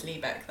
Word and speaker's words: Liebeck: I'm not going to go into anywhere near as much detail as I did with Stella Liebeck. And I think I might be mispronouncing that. I think it might be Stella Liebeck. – Is it L-I-E? – Yes. – I think it's Liebeck then.